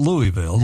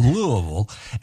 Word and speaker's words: Louisville: You got to say that right, not Louisville, Louisville.